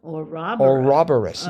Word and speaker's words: ouroboros: And the serpent that swallows its own tail, one pronunciation of it is ouroboros. – Ouroboros. – Oh. Not ooh. I was going for ooh, and it's not pronounced that. It's ouroboros. – Ouroboros. – I'm not sure, but the actual emphasis, the stress. – So there's the U is silent. It's not our. It's or.